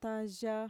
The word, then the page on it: taya: Mixtec